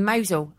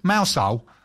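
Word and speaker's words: Mousehole: Polly and I spent a week in Mosul, I'll say it correctly, rather than you would have gone. – Mosul. – There we go. Yeah, and we visited... – I lived in Cornwall, I know it's Mosul.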